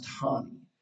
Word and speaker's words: Roney: Ronnie was the spelling, R-O-N-E-Y, pronounced Hani.